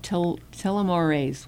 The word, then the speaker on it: telomerase: Researchers at the Ohio State University reported that high-dose omega-3 supplementation blocked the stress-related decreases in the activity of telomerase.